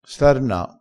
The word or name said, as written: starna